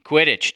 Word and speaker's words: Quidditch: 2100, my bet's on Kittich. Is that how you pronounce it? Quidditch? – Quidditch, John.